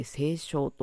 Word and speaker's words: 西湘: 足柄地域というのは、えー、神奈川県の西部、えーと、西の湘南と書いて西湘と読みますが、その地域、えー、金太郎さんのあたりの地域になります。